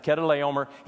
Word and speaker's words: Chedorlaomer: And it occurred between a consortium of kings, four kings in particular, led by a dude by the name of Chedorlaomer. His name is found there in the first 11 verses of Genesis 14. That's how you pronounce it, Chedorlaomer.